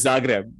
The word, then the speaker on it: Zagreb: That one I think I know is Zagreb.